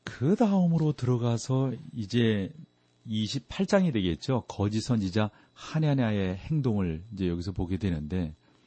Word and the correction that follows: 하나냐: 거짓 선지자 중에 하나인 하냐냐는 좀 발음하기가 좀 그러네. 하나냐는 예레미야의 예언을 거절하고 진짜 하나님의 말씀을 준다라고 또 이제 백성들을 속이는 거예요.